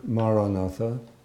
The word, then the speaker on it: Maranatha: If you choose this word, Maranatha, say it as four syllables, Ma-ra-na-tha, Ma-ra-na-tha.